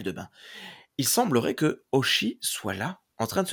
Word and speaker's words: Hoshi: J'apprends alors que Oshi s'écrit en réalité Oshi. Parce que moi, j'écrivais O-S-H-I, et en fait, ça s'écrit H-O-S-H-I.